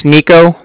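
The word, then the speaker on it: Niko: My name's Nico. That's N-I-K-O. I'm calling from the Bank of Cardiff, and that's spelled C-A-R-D-I-F-F, as in Frank Frank.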